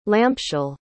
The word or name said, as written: lampshell